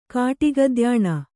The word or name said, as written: ಕಾಟಿಗದ್ಯಾಣ